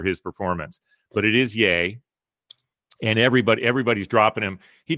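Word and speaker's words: Ye: It is pronounced. It's pronounced yay.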